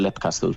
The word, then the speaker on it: Leap Castle: Fíjate, yo diría Leap Castle en su día, pero como Son Ryan lo pronuncia Leap Castle, yo me quedé con Leap Castle.